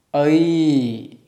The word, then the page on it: ai: Sanskrit